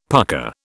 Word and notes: pucka: British English

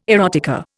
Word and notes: erotica: Indian

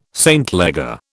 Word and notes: St Leger: British English

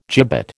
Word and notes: gibbet: American English

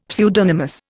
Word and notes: pseudonymous: Indian

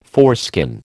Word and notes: foreskin: American English

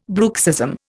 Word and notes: bruxism: Indian

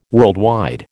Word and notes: worldwide: American English

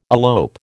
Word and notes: elope: American English

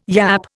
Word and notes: yap: Indian

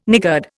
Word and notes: niggard: Indian

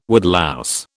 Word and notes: woodlouse: British English

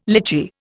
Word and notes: litchi: Indian

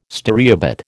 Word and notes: stereobate: American English

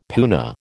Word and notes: puna: American English

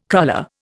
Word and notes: cruller: Indian

